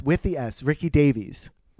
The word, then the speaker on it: Davies: Davy. I kind of, that's fun. Ricky Davy. – No. With the S. Ricky Davies. – Ricky Davies. Oh. – Yes. But it was spelled like Davis.